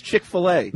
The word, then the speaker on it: Chick-fil-A: Oh, is that how you pronounce Chick-fil-A. – It? – Chick-fil-A. – Chick-fil-A. – Yes.